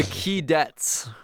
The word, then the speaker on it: Keydets: The Keydets. K-E-Y-D-E-T-S. – Well, I said they the Keydets. – K-E-Y, though. – K-E-Y. – Because cadets is spelled with C.